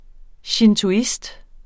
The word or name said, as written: shintoist